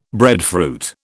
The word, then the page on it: breadfruit: British English